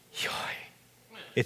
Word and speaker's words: YHWH: It's sort of, it's YHWH is how you would write it, and so how do you pronounce YHWH? Yahweh.